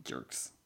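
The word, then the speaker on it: Dierks: Dirks.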